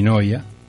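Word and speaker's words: Neuer: Así se pronuncia Noia, ¿eh? El arquero alemán Noia.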